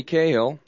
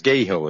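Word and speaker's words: Scahill: Cahill is the last name. – Yeah, it's S-C-A-H-I-L-L, and you can't call him Cahill. Yeah. – No, Cahill. – I thought maybe there was a silent S in there or something.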